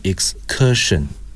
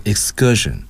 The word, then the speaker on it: excursion: 不是大家读的 excursion，应该读 excursion。那么加上 k 要转成 g，清辅音浊化，这点同学们一定要注意啊。